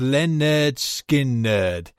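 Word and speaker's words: Lynyrd Skynyrd: Freebird, the full 9 minutes, 18 seconds from their album, pronounced Len Nerd, Skin Nerd.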